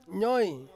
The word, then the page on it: nyoi: Ansus